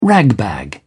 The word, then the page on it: ragbag: British English